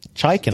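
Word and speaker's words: Chikin: Chicken.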